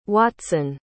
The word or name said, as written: watson